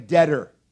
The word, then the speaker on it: debtor: It looks like debtor, doesn't it? I am debtor. That's a silent B, I think, isn't it?